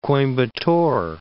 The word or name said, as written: Coimbatore